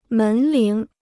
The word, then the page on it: 门铃: mén líng